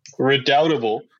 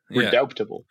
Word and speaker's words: redoubtable: Redoubtable. There's a silent B in there. Redoubt. Redoubtable. Yeah. – Comes from the French redoutable.